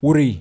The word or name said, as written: Wuri